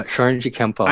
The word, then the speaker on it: Shorinji Kempo: is it Shorinji Kempo? Am I saying that correctly? – Yeah. – Okay. – Yeah, Shorinji Kempo.